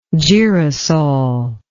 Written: girosol

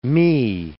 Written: mi

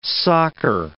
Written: soccer

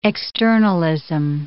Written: externalism

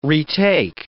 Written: retake

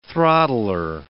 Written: throttler

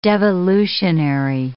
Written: devolutionary

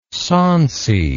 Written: sauncy

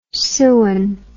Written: sewin

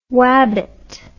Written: wabbit

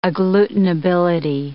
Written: agglutinability

